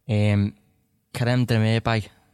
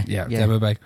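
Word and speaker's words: Kerem Demirbay: Kerem Demirbe, have I said that right? – Yes. Demirbe, was he? – Yeah. – Demirbe.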